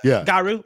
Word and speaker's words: Garou: On stage at EVO 2022, SNK announced that a new entry in the long-dormant Fatal Fury / Garou series, I don't know how to pronounce that one. – Garou? – Okay, perfect.